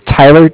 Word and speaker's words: Tyler: My name is Tyler, T-Y-L-E-R, and I'm with Bank of Cardiff. It's spelled C-A-R-D-I-F-F, as in Frank Frank.